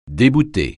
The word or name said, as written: débouter